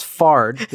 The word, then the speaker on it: Fard: Fard the Giant, which I'm just going to point out rhymes with Nard.